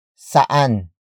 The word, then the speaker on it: saan: Saan hindi mainit? Saan hindi mainit? First, we have saan, which is spelled as S-A-A-N.